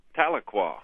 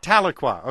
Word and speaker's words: Tahlequah: This is Tahlequah. Am I pronouncing that correctly, Oklahoma? – That's Tahlequah. – Tahlequah, okay, like Chappaqua, okay, but go ahead.